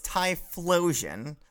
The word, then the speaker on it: Typhlosion: It's not. It's Typhlosion. There's an H in there. Oh, yeah. – It's got Typhlosion.